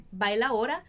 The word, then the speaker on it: bailaora: Essa palavrinha bailaora, ela é especial, porque na verdade a gente está fazendo uma redução de pronúncia. Bailaora vem na verdade de bailadora. A gente vai tirar esse D aqui de bailaora e vai ficar assim, bailaora. E isso quer dizer que é especificamente essa palavrinha aí vem desse vocabulário do mundo do flamenco.